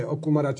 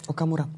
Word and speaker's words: Okamura: To je jedno, či to je Okumara... Či, Jak sa volá? Pardon. Okamura.